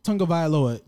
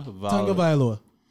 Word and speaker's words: Tagovailoa: But I can say the last name, Tagovailoa, is very simple. Tagovailoa. Say that three times fast.